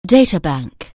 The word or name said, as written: data bank